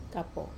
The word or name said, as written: kapo